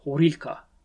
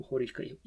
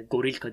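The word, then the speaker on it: horilka (горілка): Onlar e, horilka diyorlar vodkaya. Oradan da e, Ukrayna'da vodka alırsanız zaten üzerinde bu Horilka ifadesini göreceksiniz. E, Gorilka diye yazılıyor ama aslında Horilka diye okunuyor.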